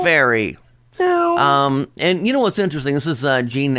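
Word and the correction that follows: Jhené: Aiko, I think is how she pronounces her name. – Jenny Aiko. – Jenny? Okay. Thank you.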